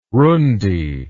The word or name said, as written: rundi